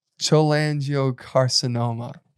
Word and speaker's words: cholangiocarcinoma: We have to actually say it right. – What? – Cholangiocarcinoma. – Cholangiocarcinoma. – Yeah. So World Cholangiocarcinoma Day.